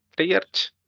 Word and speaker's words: Treyarch: Treyarch. Sí. Si, lo, pronuncio bien. – Sí, por tres.